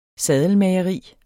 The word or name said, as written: saddelmageri